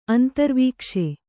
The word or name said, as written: ಅಂತರ್ವಿಕಾಸ